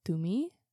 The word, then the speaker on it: Tumi: There's a place in Arizona called Tumi. And it's Tumi. It's T-U-M-I. Tumi. – Tumi. – That's just how they would pronounce it in Peru.